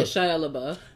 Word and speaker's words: Shia LaBeouf: Yeah, so Shia LaBeouf is really for, I hope I'm saying his name right, Shia LaBeouf. – Yeah, Shia LaBeouf. – He's really for the people too. It's not a white, black thing. He want a bus, man. He a nigga. Like, Shia LaBeouf low-key can have his buzz right now.